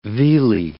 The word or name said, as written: vealy